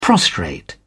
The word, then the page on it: prostrate: British English